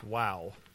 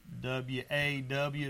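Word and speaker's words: waw: And that's wow. All right, I'll write this. Aleph, that's Beth. Yeah. But it's not wow as in W-O-W. It's W-A-W is how you pronounce it. It's spelt vow, V-A-U.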